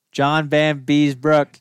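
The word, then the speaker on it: Vanbiesbrouck: John Baby Brook is overrated. – What? – I can't say his name right. – Oh, Beezer? – John Van Beesbrook.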